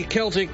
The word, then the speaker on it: Celtic: Happy Celtic Festival Day to you. What's your involvement? You're saying it right.